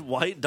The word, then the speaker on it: Wight: According to the Isle of Wight, is that how you pronounce it? White, W-I-G-H-T.